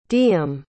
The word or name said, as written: diem